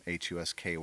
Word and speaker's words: Husky: Husky, like the dog.